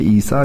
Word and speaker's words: İsa: İsa yani doğru telaffuzunu yaptım korkmayın. Efendim İbranicesi Yeşua aslında. E, Necati işte İsa Necati olmuş yani e, çok sıkı bir eğitim görmüş o döneme göre ve ana yani baba yurdu olan Kastamonu'ya dönmüş.